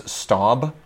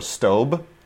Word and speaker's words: Staub: We use Staub or Staub, depending on how you pronounce it, STAUB, which is better than La Crusade.